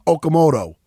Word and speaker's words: Okamoto: Oh, somebody got a little salty that I'm high on Brett Okamoto. Say it right? Did I say it right? – He did. Yeah, he just told Okamoto.